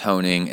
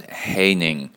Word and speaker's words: Honing: It's actually coming from Jeff Honing, and it's not pronounced Honing, it's pronounced Haining, rhymes with raining.